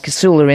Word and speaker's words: Casula: It will be places like Casula in Western Sydney. Am I even saying that correctly? – You are indeed.